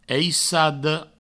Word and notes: aissada: eissado

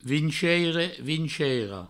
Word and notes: vinceire: vinceira; vinceiro

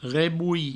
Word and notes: rebolhir: reboui

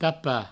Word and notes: tapar: provençal; tapa

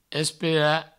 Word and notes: esperar: provençal; espera

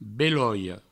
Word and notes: beloia: Provençal; beloio

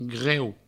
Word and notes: greu: Provençal

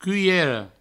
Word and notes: culhera: cuiero

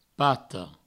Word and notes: pata: pato

